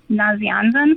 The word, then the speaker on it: Nazianzen: Saint Basil the Great and Gregory of, I know I'm going to mispronounce this, Nazianzen.